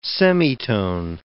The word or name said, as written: semitone